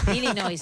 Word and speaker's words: Illinois: Así que Illinois y la S. Así que bravo, sin pronunciar bravo. La S final, Illinois, bravo, una vez más, bravo, tu apunte de geografía, muy que bueno.